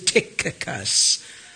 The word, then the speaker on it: Tychicus: Your Bible has a CH, but it's not pronounced like we say church. It is a hard K sound, little harder than the kappa or the Greek K. So it is Tychicus.